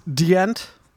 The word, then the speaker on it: Djent: And regardless of how you feel about them, uh, I, am not the hugest fan of Gent. I like animals as leaders. It's uh, pronounced Djent. Djent. it's it's Swedish.